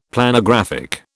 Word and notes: Planographic: British English

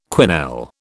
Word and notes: quenelle: British English

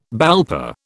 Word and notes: BALPA: British English

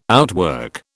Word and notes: outwork: British English

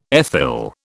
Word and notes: ethyl: British English

